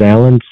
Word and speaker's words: Allan: My name is Alan Tsang, A-L-L-A-N. Last name is T-S-A-N-G, silent T-S-A-N-G.